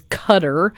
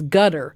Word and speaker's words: Qatar: The pronunciation of Qatar, Q-A-T-A-R, you hear it also pronounced as cutter, and you hear it also pronounced as gutter, which mm-hmm. really makes me want to throw up. But all three pronunciations are acceptable, according to the Qataris. And I know that from seeing that on the news years ago. Some newsman asked a sheik or whatever, what is the proper pronunciation?